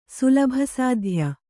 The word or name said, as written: ಸುಲಭಸಾಧ್ಯ